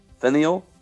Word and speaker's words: Faneuil: Oh, Faneuil.